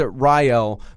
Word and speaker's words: Rayel: And the reason why I keep saying Rael is because he pronounces it Rael, but it's spelled R-A-Y-E-L.